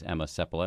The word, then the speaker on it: Emma Seppala: Her name is Emma Seppala.